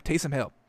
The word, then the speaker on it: Taysom Hill: Am I saying that right? Why does it sound weird now? Taysom Hill. Yeah, that's all right.